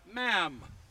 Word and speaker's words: ma'am: And ever after, in that particular visit, call her Ma'am. And you'll be told, in case you have a different sort of a way that you pronounce words, it's ma'am as in jam.